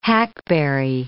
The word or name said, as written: hackberry